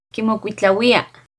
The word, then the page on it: Quimocuitlahuia: Náhuatl